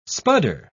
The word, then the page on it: spudder: British English